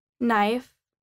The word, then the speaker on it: knife: The silent K. The K isn't generally pronounced when it comes before an N at the beginning of a word. No, knife, knock, knee, knowledge. For example, she cut it with a knife.